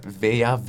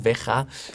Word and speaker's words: Veja: Is, is, ehm, eh, ik denk dat het Veja is dat je het moet uitspreken. Veja, Veja. Verschillende namen van uitspreken, maar dat zijn sustainable sneakers.